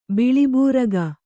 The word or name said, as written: ಬಿಳಿಬೂರಗ